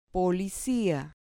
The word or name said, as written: policia